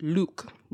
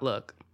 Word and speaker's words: lewk: spelled L-E-W-K, pronounced Luke, not look.